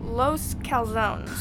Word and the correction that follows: Los Corazones: Los Corazones. It's Spanish. It means the hearts.